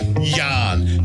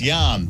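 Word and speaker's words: Jan: It's just Yarn. But actually, I think I asked one of the guys. I said, sorry, number 41, how do you pronounce it? And I'm like, oh, it's Yarn.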